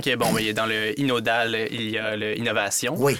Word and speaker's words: Innodal: Président chez Laboratoire, si je le dis bien, Inodal. Exactement. Et non pas Inondal.